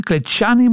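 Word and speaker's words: Clejani: Eh, Klejani wordt het uitgesproken.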